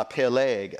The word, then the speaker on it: Peleg: Uh, Peleg uh, is how it's, it's pronounced, I believe, P E L E G, with the accent over the G, which means channel or canal.